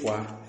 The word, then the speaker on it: foie: Quiere decir fuá.